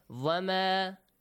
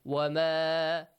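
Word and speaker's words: wama (وما): Sometimes you might pronounce the letter waw as vowel. Vama. It's not correct. Vama. Vama is not correct. Right? Because we don't have va sound in Arabic. It's wa, wa. Wama, not vama.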